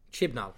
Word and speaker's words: Chibnall: Chibnall.